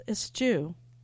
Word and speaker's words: eschew: I just looked it up, the pronunciation. It's eschew. Sorry.